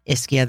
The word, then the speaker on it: Ischia: And this is spelled I-S-C-H-I-A. Also with the pronunciation on the first syllable, Ischia.